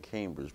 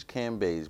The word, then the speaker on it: Cambage: Liz Cambage, I keep saying Cambridge, but it's Liz Cambage, but you're playing alongside greats like her, Elena Deladon.